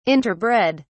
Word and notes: interbred: British English

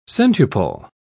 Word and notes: centuple: British English

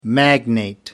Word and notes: magnet: American English

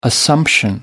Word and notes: assumption: American English

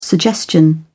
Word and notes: suggestion: British English

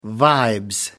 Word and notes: vibes: British English

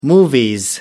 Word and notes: movies: British English

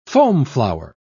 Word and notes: foamflower: British English

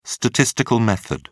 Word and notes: statistical method: British English

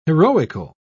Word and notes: heroical: British English

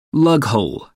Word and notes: lughole: American English

